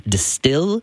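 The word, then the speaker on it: De Stijl: Heard the White Stripes right there, going back to, how do you say that album title? Distill is the J silent. And I know it's named after a Dutch art movement, translated the style.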